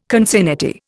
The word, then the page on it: concinnity: Indian